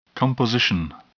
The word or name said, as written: composition